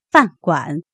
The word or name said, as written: fan4guan3